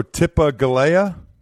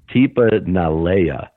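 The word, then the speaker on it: Tipa Galea'i: I'll go Tipa Galea. – Pretty close. It's Tipa Nalea. It's interesting that it's with a G, but it's like, from what I've heard, it's pronounced like almost like an N.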